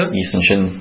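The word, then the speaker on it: Yi Sun-sin: One was Lee Soo-shin, Am I correct? Was that the pronunciation correct? – Yi Sun Shin, Admiral.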